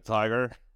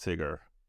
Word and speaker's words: Tykr: Is it like a tiger? Like a Tykr? – Tykr, like a ticker symbol. – Like Tykr, right.